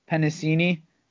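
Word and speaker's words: Penisini: Nose tackle John Pennacini from Utah. So did I say that right? – Nailed it.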